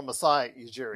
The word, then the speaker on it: Masai Ujiri: I can't never pronounce his name. Majuri. – Oh, Masai Ujiri.